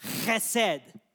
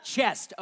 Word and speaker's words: chesed: It's a Hebrew word, and you have to use it with like the heavy guttural. So if you're not spraying with the pronunciation of this, you're not doing this right. It's not chest, okay? It's not chest. It's chesed, okay?